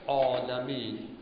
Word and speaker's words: العالمین: گفتیم مثل عالمین، آ آ. یه بار شما میگه الحمدلله رب العالمین، آیه عالمین شد عالمین، مثل آیه که آب مینویسن، یه باید غلطه.